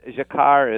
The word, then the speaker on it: Jacquard: Mine is French, yeah. Yes. Jacquard is how you say it in French.